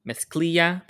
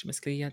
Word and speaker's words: mezclilla: They present the challenge, which is to create a look out of denim, which is mezclilla. That's how you say it in Spanish, mezclilla, denim.